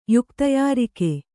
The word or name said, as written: ಯುಕ್ತಯಾರಿಕೆ